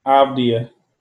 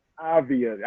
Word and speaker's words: Avdija: Avdija. It's like the J is silent. Avdija.